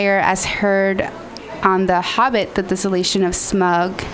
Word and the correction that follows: Smaug: Smug? – I think it's Smaug.